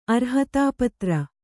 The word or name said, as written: ಅರ್ಹತಾಪತ್ರ